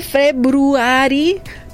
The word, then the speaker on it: February: Se escribe February, pero se dice February.